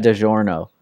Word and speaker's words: DiGiorno: It tastes like DiGiorno. – Yeah. DiGiorno? I don't know how to say it. – DiGiorno. – DiGiorno? – Yeah.